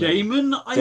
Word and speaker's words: Gaiman: How do you say it? Gaiman or Gaiman? – Gaiman, definitely Gaiman.